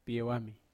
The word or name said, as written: piewami